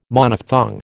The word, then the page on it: Monophthong: American English